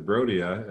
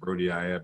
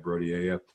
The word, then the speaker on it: Brodiaea: I am happy that you mentioned that one because Brodia, as I usually hear it pronounced, Brodiaea, Brodiaea, are California native.